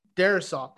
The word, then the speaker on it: Darrisaw: God, Darwinson or something like that. – Oh, Christian Darrisaw? – Darrisaw. Thank you. I'm apologies for butchering his name.